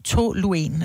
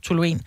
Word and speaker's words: toluen: Blandt andet det stof, som hedder, jeg mener, det udtales toluen, eller toluen, som er noget, som man faktisk kan sniffe, og det går ind og påvirker dit nervesystem.